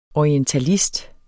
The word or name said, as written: orientalist